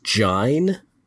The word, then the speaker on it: join: Join is pronounced jine. That O-I.